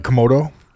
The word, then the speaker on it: Komodo: Komodo? – Yeah.